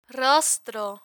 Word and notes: rostro: spanish

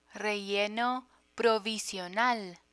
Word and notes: relleno provisional: Spanish

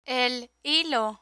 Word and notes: el hilo: Spanish

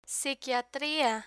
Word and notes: psiquiatria: Spanish

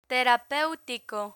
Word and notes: terapeutico: spanish

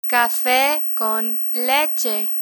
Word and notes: cafe con leche: spanish

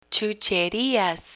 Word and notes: chucherias: spanish

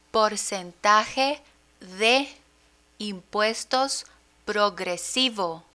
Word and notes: porcentaje de impuestos progresivo: Spanish

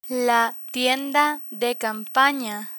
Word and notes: tienda de campana: spanish

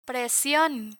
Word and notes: presion: Spanish

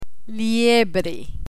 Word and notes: liebre: spanish

